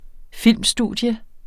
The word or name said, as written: filmstudie